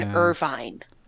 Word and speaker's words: Irvine: I think you mean Irvine. – Irving. Wait, how do I say that? – I think it's Irvine. Irving? I've never heard of Irving, California, but Irvine in Orange County has... No, Irvine.